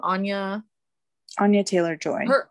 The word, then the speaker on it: Anya Taylor-Joy: Anya Taylor-Joy.